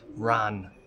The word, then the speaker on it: wren: Um, and in Hiberno English, by the way, people would often pronounce the English word ran instead of wren.